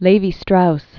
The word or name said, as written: Claude Levi-Strauss